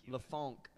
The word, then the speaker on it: LeFonque: Wait, F-O-N-Q-U-E. Funk. LeFonque. Yeah.